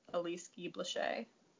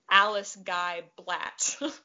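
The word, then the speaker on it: Alice Guy-Blaché: Yeah, Be Natural: The Untold Story of Elise Guy Blaché. – Amazing. – Yeah, and for all of us Americans, her name looks like it's spelled Alice Guy Blatt, but it's not.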